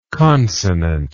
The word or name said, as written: consonantly